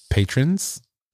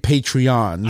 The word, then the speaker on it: patrons: Patrons. – Yeah. – Did I say it right? – Yeah. – You see, I'm used to saying Patreons. – I know. That's why. – Patrons.